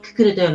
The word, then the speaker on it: 心: 沖縄本島の中部地区恩納村にて、宿泊施設飲食店の心と書いてククルと読むククルの宿及び養鶏場を営んでいる野葉宗信さんです。